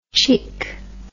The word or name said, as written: tchick